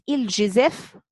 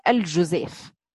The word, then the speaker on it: الجزاف: نقول الجزاف ونقول الجزاف، تنطق باللفظتين، ويقول العلماء إن لفظة الجزاف أفصح من الجزاف بضم الجيم، يعني الكسر أفصح من الضم، تقديرا من غير وزن ولا كيل ممتاز إلهام ولا عد أيضا، يعني بيع بالتخمين هذا من غير إيه؟